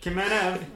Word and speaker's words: Kamenev: I got Vladislav Kamenev. Am I saying that right? – Hit it, Kamenev. Yep.